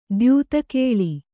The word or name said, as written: ದ್ಯೂತಕೇಳಿ